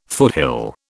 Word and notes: foothill: British English

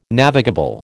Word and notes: navigable: American English

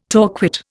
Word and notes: torquate: Indian

